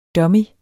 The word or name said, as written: dummy